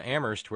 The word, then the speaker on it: Amherst: Terry in Amherst, where only the H is silent, said Rami Malek is phenomenal as Freddie Mercury.